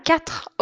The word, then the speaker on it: quatre: Dans mon salon, il y a quatre objets orange. Quatre, quatre. Presta attention, a gente não fala quatre, apesar de se escrever com a letra U. Q-U-A-R-T-E.